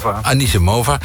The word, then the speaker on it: Anisimova: Want tennisser Amanda Anisimova, zeg ik dat goed? – Anisimova ging viral nadat ze een biertje leek te drinken tijdens een wedstrijd.